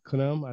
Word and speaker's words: Khnum: You ever heard of the Egyptian god Khnum? I think it's spelled K-H-N-U-M. Khnum, I think that's how you spell it.